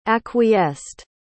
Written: acquiesced

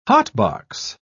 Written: hotbox

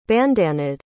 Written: bandanaed

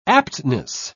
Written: aptness

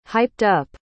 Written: hyped-up

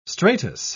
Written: stratus

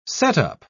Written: setup

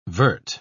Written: vert

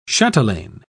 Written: chatelaine

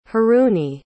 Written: haruni

